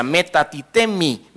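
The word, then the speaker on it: metatithemi: Y la palabra trasponer, aquí viene de un vocablo griego también que se pronuncia metatitemi, que significa transferir a otro lugar o traspuso.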